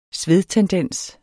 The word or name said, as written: svedtendens